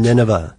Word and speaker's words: Nineveh: So, such as the book of Judith, where it said Nebuchadnezzar II was the king of, what is it, Nineveh? – Oh, I don't know how to say it. – Nineveh.